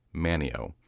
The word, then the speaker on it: Manteo: One of the two's name was Manio, which is spelled M-A-N-T-E-O. There is a coastal town in North Carolina of the same name, and the people around there have instructed me that it's pronounced Manio, despite there being a T in the word.